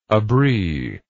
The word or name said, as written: abri